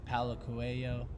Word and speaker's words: Paulo Coelho: One of our book we listened to earlier this year as we road tripped across Canada was The Alchemist, Paulo Coelho, who a Brazilian told me pronounced it correctly.